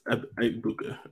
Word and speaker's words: Egbuka: It's Egbuka.